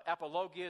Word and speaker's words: apologia: Now, you might know the word apologia, apologia is how you pronounce it in Greek, that's used in this word as a defense.